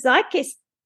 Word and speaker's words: Zeitgeist: And I can't remember if we said congratulations to Joe for being signed by the Zeitgeist.